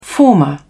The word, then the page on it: former: British English